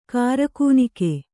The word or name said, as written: ಕಿರ್ಗಣ್ಣಿಕ್ಕು